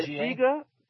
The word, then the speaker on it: Dziga: The is it pronounced Ziga? D-Z-I-G-A? – Yeah, Ziga, named after the Soviet documentary filmmaker, Ziga Vertov.